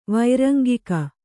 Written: ವೈರಂಗಿಕ

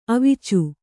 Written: ಅವೀಚಿ

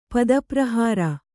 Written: ಪದಪ್ರಹಾರ

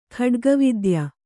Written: ಖಡ್ಗವಿದ್ಯೆ

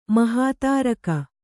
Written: ಮಹಾತಾರಕ

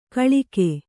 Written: ಕೊಳಕು

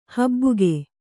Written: ಹಬ್ಬುಗೆ

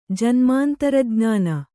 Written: ಜನ್ಮಾಂತರಜ್ಞಾನ

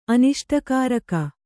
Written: ಅನಿಷ್ಟಕಾರಕ

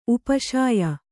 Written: ಉಪಶಾಯ

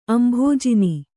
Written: ಅಂಭೋಜಿನಿ

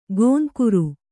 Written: ಗೋಂಕುರು

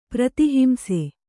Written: ಪ್ರತಿಹಿಂಸೆ

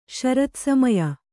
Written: ಶರತ್ಸಮಯ

